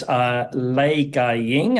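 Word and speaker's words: Lai Ka-ying: And she is Lei Gaiying, that's how it's pronounced in Cantonese.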